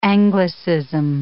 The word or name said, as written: anglicism